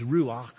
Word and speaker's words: ruach: In Hebrew, it is ruach. Comes from the back of your throat there.